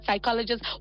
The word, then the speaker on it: psychologist: like say the word psychologist, why is the P there, the silent letter, please? – Yeah.